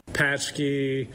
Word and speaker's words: Paczki: But P-A-C-Z-K-I is pretty unpronounceable for most Americans. – Paczki. We don't hear too many people that actually call it by the correct name. – That's the owner of Rise and Roll franchises in Fort Wayne. – The correct pronunciation is going to be Paczki.